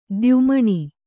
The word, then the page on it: ತಾಯಿಮನೆ: tāyi mane